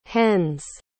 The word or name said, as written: Hennes